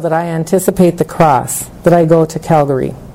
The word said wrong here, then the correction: Calvary: Am I saying that right? Calgary. – Calvary. – Why am I mixing that up? Calvary.